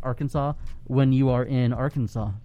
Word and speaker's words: Arkansas: It's pronounced Arkansas, but some people call it Arkansas. – I see. – And it is illegal to pronounce it Arkansas.